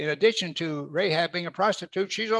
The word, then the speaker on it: Rahab: Likewise was not Rahab, however you want to pronounce it. I'll say Rahab. Most people say it that way.